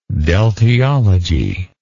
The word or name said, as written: deltiologist